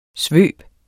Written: svøb

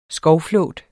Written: skovflåt

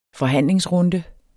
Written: forhandlingsrunde

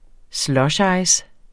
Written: SLUSHICE

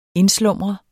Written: INDSLUMREDES

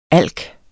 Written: alken

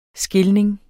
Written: skilning

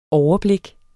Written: overblik

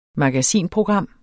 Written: magasinprogram

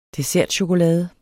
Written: dessertchokolade

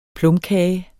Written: plumkage

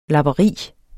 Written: lapperi

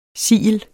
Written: sigel

